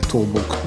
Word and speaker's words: 東北: 東北と書いて倒木なるんですけどね、泉式部という人が指定で、まあ、 3番目ものですね。